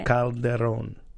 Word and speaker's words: Calderón: Calderon. Roll the R. Try it. – Calderón. – There you go. A little more.